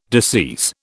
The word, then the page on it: decease: British English